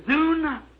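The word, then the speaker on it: Zoon (ζῷον): What is Zoon? Z-O-O-N? Living creatures. It might be pronounced Zon, Z-O-O-N. How would you pronounce that? Zoon? Zoon, I'd say. And Theron is T-H-E-R-O-N, Theron, see?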